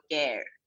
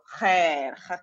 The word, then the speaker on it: Khair: I kept saying Khair, and everyone thought it was called Care. – Right. – Okay. And then I had to really make the effort to go Khair. Like that's how I pronounce in practice.